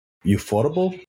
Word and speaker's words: Ufotable: Ah, nunca he sabido cómo se dice este nombre de este estudio de animación, UFO Table o Ufotable. – Ufotable.